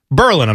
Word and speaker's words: Berlin: Berlin, I'm sorry. – Not Berlin, like Germany.